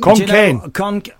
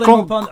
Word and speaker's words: Khon Kaen: Ross Lacey, Konkan, Thailand. – Is that how you say it? – Konkan. – I don't think that's right. Is that right? – Should it is. It's Konkan. K-H-O-N-K-A-E-N.